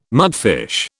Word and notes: mudfish: British English

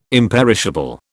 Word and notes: imperishable: British English

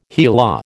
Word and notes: HeLa: American English

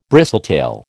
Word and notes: bristletail: American English

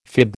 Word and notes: fib: American English

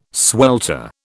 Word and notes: swelter: British English

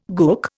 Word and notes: gook: Indian

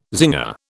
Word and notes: zinger: British English

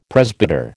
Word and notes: presbyter: American English

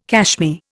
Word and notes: cashmere: Indian

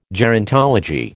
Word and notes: gerontology: American English

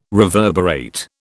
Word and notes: reverberate: British English

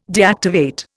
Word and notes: deactivate: Indian

